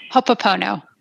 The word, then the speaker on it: Ho'oponopono: I love the Kupono component. Am I saying that right? – Ho'oponopono. – Ho'oponopono, thank you.